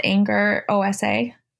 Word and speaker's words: Angerosa: It's my first name, which is Cass, CASS, and then my last name, which is spelled anger, OSA.